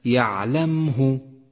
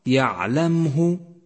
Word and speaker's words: يعلمه: سادسا، احذر من اللحن عند قراءة حرف الميم الساكن في كلمة يعلمه يعلمه، الميم ساكنة وليست مضمومة، يعلمه وليس يعلمه، وهذا خطأ شائع فتنبه.